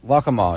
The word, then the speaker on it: Waccamaw: and I'm probably going to say this wrong, the Waccamaw River? Is that right? – Waccamaw.